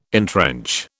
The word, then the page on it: entrench: British English